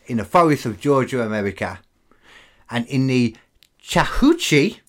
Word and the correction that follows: Chattahoochee: No, Chattahoochee. – Chattahoochee. Even better, extra syllable there.